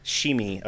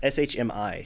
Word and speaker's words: Shmi: just listed no, as Shimi, or I can't say it right. It's S H M I, Shimi or Shmi.